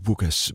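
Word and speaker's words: Łukasz: Det staves altså med et L først, eller et slags L, det udtales Vukas Borovitski.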